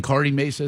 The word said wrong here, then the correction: Kearney Mesa: is that where that is? – Kearney Mesa. – Yeah. – Yeah. – I'm sorry. – It's actually mispronounced. – Pretty close. – Kearney Mesa. – Yeah, thank you.